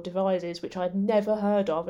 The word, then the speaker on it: Devizes: House of, I should controversially say, I say Devizes. There's a whole story behind this, by the way. But it's actually pronounced Devizes.